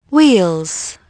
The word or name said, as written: wheels